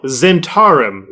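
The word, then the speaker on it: Zhentarim: Oh, Zentarum. Zentarum. I've been pronouncing that incorrectly.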